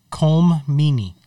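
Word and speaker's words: Colm Meaney: You said Cole Meany? – Colm Meany. – Okay. You did not say his name right, but I was like, Colm Meany.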